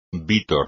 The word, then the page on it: vítor: espagnol